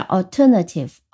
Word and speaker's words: alternative: 好，再来一个相对长一点的词，它其实也是一个名词，呃，表达的是替代方案，叫 alternative。 alternative。先来看图音，其前面的 a l 读成 all。那我们知道 a l 读成 all 的有什么 ball 啊？比如说，对吧？这些都是呃 a l 是发音成 all。